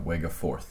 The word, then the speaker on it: Wegeforth: So you work in like the actual area called the Wegafirth Bowl, right? Is that how you say it? Uh, ah, Wegeforth, yes.